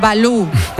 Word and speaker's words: Valou: Este Balú es con V, ¿eh? No es como el oso. – Sí, Yanis Balú. V-A-L-O-U.